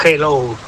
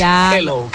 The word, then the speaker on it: hello: Y la segunda, dile a Elena que su dicción en castellano es la más perfecta que conozco, porque cuando diga hello, que no pronuncie la J, que no es hello, que es hello, que la aspire la J y no pronuncie la J potente castellana.